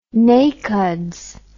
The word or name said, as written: NACODS